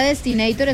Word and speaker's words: Destinator: ¿Se dice Destinator o cómo se dice? – Sí, es la Destinator, es un vehículo nuevo, lo lanzamos apenas el 2 de junio acá en la oficina de Ibagué.